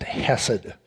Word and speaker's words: hesed: He can do that because of his reliance on an attribute of God that is wrapped up in a word, a Hebrew word pronounced hesed. And actually, if you're supposed to pronounce it right, you should have a little cough in there, kind of a K-H, and I'm not going to try to do that.